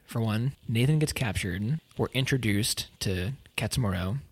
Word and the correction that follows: Katsumoto: Is it Katsumoro or Katsumoto? Katsumoto. Okay, I've been saying it wrong this whole time.